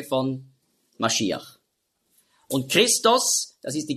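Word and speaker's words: Mashiach (משיח): Also darum wurde aus Masch, wurde Mess, Und dann Iach mit dem Het am Schluss, Mashiach, hat man früher ausgesprochen, Mashiach.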